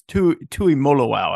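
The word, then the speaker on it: Tuimoloau: I want to say, and this has been a difficult pronunciation for me because I want to say just sort of based on phonetics, Tui Mololau, but you sort of just flow through all of it. It's Tui Mololau, I believe, is the way he prefers it.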